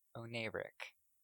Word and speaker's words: oneiric: Oneric. – Oneric. Can you spell that for me? – Yes. I was like, how do I pronounce this again? Oneric. O N E I R I C. Oneric.